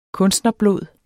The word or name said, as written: kunstnerblod